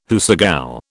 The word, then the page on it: hoosegow: British English